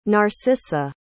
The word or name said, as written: narcisa